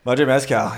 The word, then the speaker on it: Madre Mezcal: Madre Mezcal.